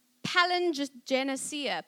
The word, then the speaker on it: Palingenesia: And the word regeneration comes from the Greek word, and I went to Google and I said, How do I pronounce this, Google? Because I was going to butcher it. Palingenesia, palingenesia, apparently that's how you say it.